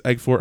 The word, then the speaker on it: Elgort: Ansel Elgort, I think it's Elgort, how you pronounce his name.